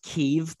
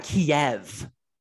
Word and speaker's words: Kyiv: We all went from, if you're not supporting, it's pronounced Kyiv, by the way. People who have never even don't even know where the Ukraine is, it's pronounced Kyiv. All right. And it's not Kiev.